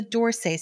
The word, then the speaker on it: d'Orsay: Today's word of the week is a shoe style that's been everywhere for the last couple of years, and that's the Dorsay style. It's spelled D'ORSAY, pronounced like, what does the door say?